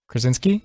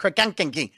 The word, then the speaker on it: Krasinski: Krasinski? – No, he gets it right. Before the show, he was like, Krakankinki.